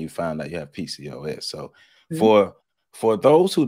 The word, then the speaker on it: PCOS: It's PCOS or POCS? – PCOS.